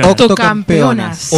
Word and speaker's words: octocampeonas: Octocampeonas. Octocampeonas. – así se dice.